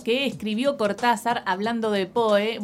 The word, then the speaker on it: Poe: Bueno, unifiquemos porque en realidad es Edgar Allan Poe, pero algunos acá le decimos Edgar Allan Poe, pero en realidad se dice Poe. – Se dice Poe, efectivamente. – Poe en inglés. – Pero se escribe Poe, P-O-E, como poesía sin sílaba.